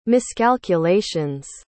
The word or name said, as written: miscalculations